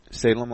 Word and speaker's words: Salem: Salem like the cigarette. – I don't know how to spell that. S-A-L-U-M? – E-M. E-M. – S-A-L-E.